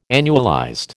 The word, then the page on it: annualized: American English